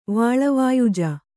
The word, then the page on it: ವಾಳವಾಯುಜ: kannada